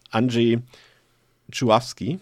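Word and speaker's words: Andrzej Żuławski: Die kompletten 124 Minuten Regie geführt hat Anjay Chuawski. Ich habe es nochmal geübt. Das wäre die richtige, äh, die richtige Aussprache des Namens, falls wir das jetzt irgendwann nochmal äh, falsch aussprechen im Laufe des äh, Podcasts.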